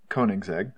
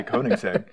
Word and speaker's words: Koenigsegg: Koenigsegg. I know Koenigsegg. – Yeah. – Yeah. I drive a Swedish car. As soon as you do, you know how to say Koenigsegg.